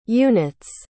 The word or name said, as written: unit's